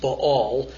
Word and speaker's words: Baal: That's the proper pronunciation. I've had a Hebrew speaker in Israel, you know, emphatically say it should be pronounced Baal.